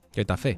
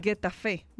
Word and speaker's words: Getafe: Getafe. – Getafe, ¿verdad? – Uh-huh.